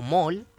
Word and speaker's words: mole: Y obviamente solo en España, porque en inglés tienen otros nombres que se llaman mole o mol, ¿cachai? Se escribe mole, se debe pronunciar como mol, no está la teacher Marta acá.